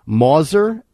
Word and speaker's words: Mauser: They pulled his DNA off of the actual, I'm going to butcher the name of the gun, Mauser, M-A-U-S-E-R, a Mauser 98 itself, trigger, bolt handle, everywhere you touch if you were working the action.